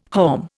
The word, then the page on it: haulm: Indian